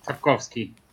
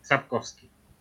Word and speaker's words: Sapkowski: Sapkowski, yes. – So it's a V sound, Sapkowski? – Sapkowski. – Okay, and then how do you pronounce the first name? A-N-D-R-Z-E-J.